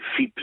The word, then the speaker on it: FIP: Dus FIP, VIP spreek je het uit.